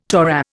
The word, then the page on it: Torah: Indian